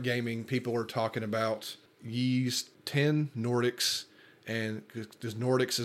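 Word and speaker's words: Ys: so the newest Yi's game, and Yi's is Y-S, it's hard to pronounce,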